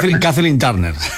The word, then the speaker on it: Kathleen Turner: es Kathleen Turner. – Kathleen Turner.